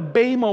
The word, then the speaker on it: bema: The Greek word that he uses here for judgment seat, perhaps you've heard it before, is the word bema, B-E-M-A, pronounced bema.